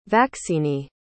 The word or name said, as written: vaccinee